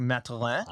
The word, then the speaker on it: Mathurin: Tyrese Halliburton, Benedict Mathurin if you pronounce it the French Canadian way, Benedict Matelin.